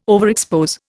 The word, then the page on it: overexpose: Indian